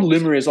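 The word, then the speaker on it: Lumryz: It's called Lumeris. I'll spell it L-U. – Yeah. – M-R-Y-Z.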